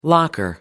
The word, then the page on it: locker: American English